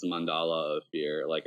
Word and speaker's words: Mandala: I was calling it Mandala of Fear for a long time, and then Amy corrected me because that's not right. It's Mandala of Fear, Mandala.